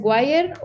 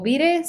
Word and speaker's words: Wire: una es Wire o Wire o Vire, según como pronuncian la doble E.